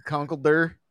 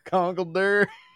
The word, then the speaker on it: Conkeldurr: Conkeldurr, is that? – That's pretty close. – Conkeldurr.